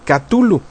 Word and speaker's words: Cthulhu: Aunque yo de metiche me encontré por ahí un libro de Derleth, una carta de Lovecraft en la que debaten el punto y dicen que la traducción correcta es Cthulhu. Yo lo voy a seguir pronunciando con Cthulhu.